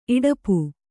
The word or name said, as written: ಇಡಪು